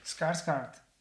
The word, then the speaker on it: Skarsgård: Skargard. ¿Cómo se pronuncia el apellido? – Skarsgard. Skars, Skarsgard.